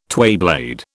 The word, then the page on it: twayblade: British English